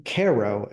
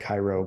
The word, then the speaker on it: Cairo: And they forge this friendship, and they are trying to get Jim to Cairo, Illinois. I mean, it's spelled like Cairo, but you know how we are.